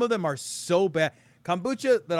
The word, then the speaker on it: kombucha: Kombucha.